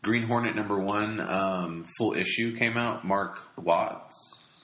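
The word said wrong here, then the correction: Mark Waid: Mark Wade.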